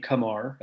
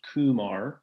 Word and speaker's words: Kumar: And that was great, because Kumar Rocker which we learned, and this is important, we learned his preferred pronunciation of his first name, Kumar, as opposed to Kumar. So good to know there,